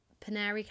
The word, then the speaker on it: Poenari: He captured the noblemen and forced them into hard labour, where they built, I think it's pronounced Penary Castle. – Okay. – So they were forced to build that. And I mean, I think if you Google it, it's Poenari, is literally on top of a mountain.